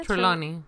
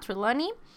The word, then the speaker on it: Trelawney: Trelawney. – Ajá, Trelawney.